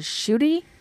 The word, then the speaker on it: Ncuti: Hakuna Matata's real name is Shuti. Although you wouldn't know it, but because it's spelled N-C-U-T-I, but that's how it's pronounced.